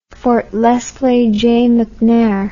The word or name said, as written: Fort Lesley J McNair